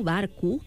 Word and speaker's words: barco: Però, esclar, aquesta O final, pronunciada com una U, barco, oi, que diem,